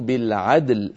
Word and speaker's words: بالعدل: فيجب بيان الدال وقلقلتها ويجب بيان اللام وبينيتها هكذا. أن تحكموا بالعدل، فنقلقل الدال ونبين شدتها ونبين ونعطي اللام حقها من البينية، بالعدل دون أن تميل الدال إلى شيء من الكسر عفوا، فتبقى الدال ساكنة واللام كذلك ساكنة.